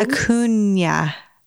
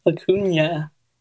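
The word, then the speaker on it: Acuña: It's Acuna. – Acuna.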